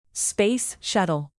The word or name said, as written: space shuttle